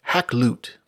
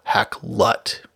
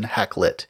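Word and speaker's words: Hakluyt: which says his name was almost certainly pronounced Hacklut, though they don't actually say that's how members of the society actually pronounce the name today. I also see now that Wikipedia offers 3 different pronunciations, none of which have quite as fronted a vowel, so not a short I, but something more like a schwa, Hacklut, rather than Hacklit.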